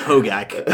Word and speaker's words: Hogak: Nobody pronounces it correctly. We have it. It is Hogak. I've heard it wrong on several podcasts.